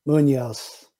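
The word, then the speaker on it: Muñoz: So it's Carlos Munoz. – Okay. You had something you were like, it rhymes with. – Yeah, it pretty much rhymes the first name and the last name.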